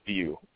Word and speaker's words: Wiuff: Well, his name is spelled W-I-U-F-F, okay? You wouldn't get View from that any way you look nope. – at it. You're never going to look at that and say View, ever. You know what I mean? So I find out that's what the pronunciation is.